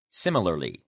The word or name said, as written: similarly